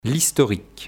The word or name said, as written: L'historique